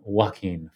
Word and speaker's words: Joaquin: and so for listeners that aren't aware, that it's a film where a character played by Joaquin Phoenix, I'm probably butchering his name. – Joaquin Phoenix, thank you.